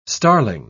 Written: starling

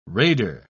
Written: raider

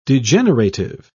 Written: degenerative

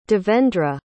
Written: devendra

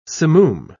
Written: simoom

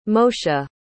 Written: moeshe

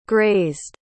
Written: grazed

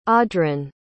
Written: odhran

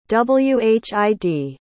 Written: whid

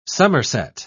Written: somerset

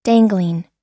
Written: dangling

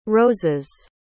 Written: roses

